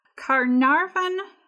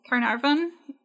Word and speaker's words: Caernarfon: Carnarvon in the book is spelled C-A-E-R-N-A-R-V-O-N. That is the old spelling and the anglicized spelling of this town. And the town currently is spelled with an F instead of a V, so it would be C-A-E-R-N-A-R-F-O-N. That's the Welsh spelling. A single F has a V sound.